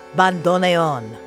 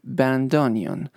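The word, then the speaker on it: bandoneón: Guys, two words. First, band, and then onion. Bandoneon. So that's a way to pronounce it in English. In Spanish, we say bandoneon.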